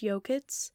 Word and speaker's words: Yokuts: And I believe it's Yokuts.